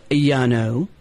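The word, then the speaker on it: Llano: But it is Yano. That's L-L-A-N-O. Pronounced Yano Brewery, and it's Blush.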